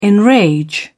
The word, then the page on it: enrage: British English